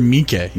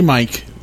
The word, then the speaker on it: Miike: Is it Miike or Miike? – Miike. Let's get that out of the way real quick, actually. – Yeah, let's get this right. Let's get our pronunciations down. Miike. – Miike. – Takashi Miike. – Takashi Miike. – Not Takashi Mike.